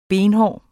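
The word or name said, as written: benhård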